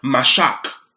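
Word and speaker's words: mashach: Now, the word anointed in the Hebrew is the word mashak.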